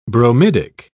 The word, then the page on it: bromidic: British English